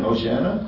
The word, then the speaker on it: Hosanna: How do you say it again? Hosanna? – Hosanna.